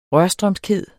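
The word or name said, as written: RØRSTRØMSKHED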